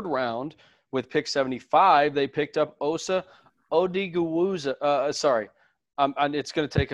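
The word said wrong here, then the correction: Odighizuwa: It's Odigazua, I believe. – Say again? Huh? – Odigazua, I think. – Yes, Odigazua, the defensive tackle out of UCLA.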